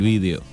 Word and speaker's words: vídeo: La tele, sí, Siempre presto atención en, los tutoriales, sobre todo de, de españoles y el video sí, el vídeo. Y se escribe con tilde en la I, ¿eh?